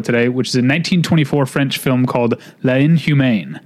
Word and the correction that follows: L'Inhumaine: I'm not sure if I'm saying that right. It's L'Inhumaine. It means the inhuman woman.